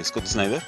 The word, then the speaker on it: Scott Snyder: Entonces, pues, que de hecho, fíjate que era lo que estaba platicando Scott Snyder.